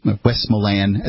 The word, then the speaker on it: Westmoreland: I am familiar with this myself, having gone to plenty of town meetings in Westmoreland, New Hampshire, Westmoreland, as they pronounce it here, and.